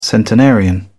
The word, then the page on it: centenarian: British English